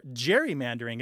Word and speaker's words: gerrymandering: And one of the participants says, "You are constantly gerrymandering the state," or gerrymandering, as I maintain the word should be pronounced, the G being soft.